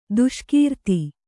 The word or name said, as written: ದೇಸೆಗಾರ್ತಿ